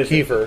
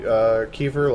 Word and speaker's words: kefir: Well, I say kefir. – It? Yeah. – Yeah, now. I used to say kefir, but Courtney told me that it was the other way, so.